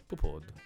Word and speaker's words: Poupaud: Brad Pitt, Angelina Jolie e Melanie Laurent e Melville Poupaud. Poupaud, così.